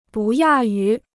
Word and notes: 不亚于: bù yà yú

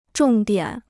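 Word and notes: 重现: chóng xiàn

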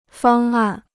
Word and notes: 方案: fāng àn

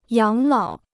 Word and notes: 养老: yǎng lǎo

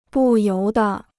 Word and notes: 不由得: bù yóu de

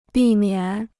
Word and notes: 避免: bì miǎn

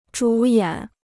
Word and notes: 主演: zhǔ yǎn

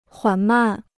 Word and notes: 缓慢: huǎn màn

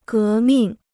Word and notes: 革命: gé mìng

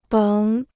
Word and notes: 甭: béng

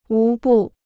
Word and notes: 无不: wú bù

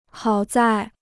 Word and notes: 好在: hǎo zài

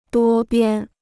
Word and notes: 多边: duō biān

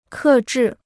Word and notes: 克制: kè zhì

